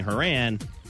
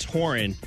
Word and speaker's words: Horan: I was saying Horan. It's Horan.